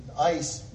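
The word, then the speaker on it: ice (εἰς): It's pronounced ice, like an ice cube or ice bucket.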